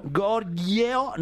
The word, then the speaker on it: Goryeo: la dinastía Goryeo Gorgeo. Gorgeo, no sé si lo estoy pronunciando bien. – Sí, es perfecto eh, tu coreano.